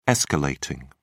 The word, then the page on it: escalating: British English